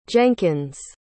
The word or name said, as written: jenkins